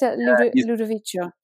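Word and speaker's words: Ludovico: Or was it Ludovisio Oendi as well? Is that how you say his name? Do you know him? Ludovisio. – Say that again. Oh, yes. Ludovicio. – Yeah, I've definitely said that wrong.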